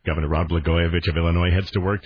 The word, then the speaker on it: Blagojevich: Disgraced Illinois Governor Rod Blug. Have we figured out how to say this guy's name yet? – It's Blagojevich. – Blagojevich.